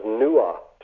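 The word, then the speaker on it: Nuada: This is all from from Irish mythology, in which the sword of King Nada was called Retaliator. You know, and Nada, one of the other pronunciations of his name was Nua, just like Noah.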